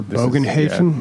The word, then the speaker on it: Bogenhafen: The Shadows over Bogenhafen? – Yeah, this is it. Yeah. – Nice pronunciation there. They stuck the landing on that one.